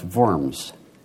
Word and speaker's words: Worms: The Diet of 1521 met in the German city of Worms, correctly pronounced Worms.